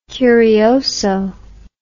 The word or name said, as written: curioso